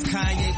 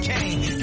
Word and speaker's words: Kanye: Now all I need is y'all to pronounce my name is Kanye, but some of my plaques still say Kane.